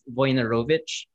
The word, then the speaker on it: Wojnarowicz: So the three documentaries that I really, really liked this year, one is called, it's spelled Wojnarowicz, but it's pronounced Wojnarowicz.